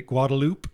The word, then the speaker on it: Guadalupe: And what are they? Guadalupe? Isn't that yes. – how they pronounce it? – Yes. That's how they do it.